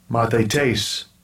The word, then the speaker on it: mathetes: So it's M-A-T-H-E-T-E-S. We want to pronounce it. We hear it here. – Strong's G-3101. Mathetes. – That one's a challenge. Mathetes is how we would say it.